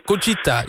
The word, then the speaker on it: Conchita Wurst: Conchita Wurst, zeg ik het goed? – Ja, Conchita, ja.